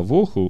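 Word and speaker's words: боху (בֹהוּ): И при этом написано Боху. Когда ва воху, ва это предлог и, и вместе с ва тогда бо читается как в. Если просто и убрать, тогда боху.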